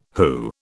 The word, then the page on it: WHO: British English